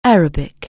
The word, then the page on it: Arabic: anglais